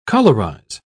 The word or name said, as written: colorize